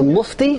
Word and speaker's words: Mufti: about the Mufti, is that how you pronounce it? – Right.